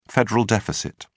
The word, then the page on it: federal deficit: British English